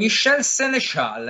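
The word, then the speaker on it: Michel Sénéchal: Michel Seneschal, è, Michel un Seneschal, parigino, è un parigino.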